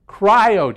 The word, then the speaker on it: cryo: I'm going to say it wrong. It's Cairo, something or other chamber. You say, what is that? It's this, what is it? Cryo chamber.